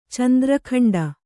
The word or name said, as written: ಚಂದ್ರಖಂಡ